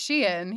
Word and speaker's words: Shein: And I'd say that I saw today or yesterday, I think it was actually, that did you see that Shine or Sheen or however you say it? How do you say it? – I think it's Shein, yeah.